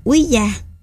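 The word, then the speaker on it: Ouija: Bản cầu cơ, hay còn gọi là bản quy tra, xin đọc là quý gia, được phát minh ra ở Mỹ vào năm 1892 bởi Elijah Paul.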